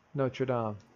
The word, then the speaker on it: Notre Dame: How do you say that in French? Notre Dame.